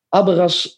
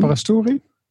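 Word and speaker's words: Aberasturi: Daar won John Abras. Nou, dit kan ik al helemaal niet uitspreken, Jeroen. – Abrasturi.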